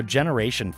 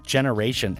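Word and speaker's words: Gener8ion: For those unfamiliar, which I certainly was as well prior to hearing this and looking in a bit more, Generation, and that's spelled with an 8 like Jenner 8 ION,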